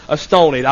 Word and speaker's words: astonied: I am pronouncing that word correctly. It looks like astonished, but it is pronounced astonied.